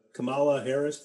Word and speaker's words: Kamala Harris: Biden selects uh, Kamala Harris.